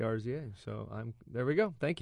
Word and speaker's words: RZA: It's RZA.